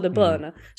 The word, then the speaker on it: De Bruyne: Då vill jag bara tala om att jag själv frågade honom hur man uttalar hans namn. Och han sa De Bruyne. Så jag känner att om Kevin De Bruyne säger att man ska uttala det som Kevin De Bruyne, då kommer jag fortsätta göra det.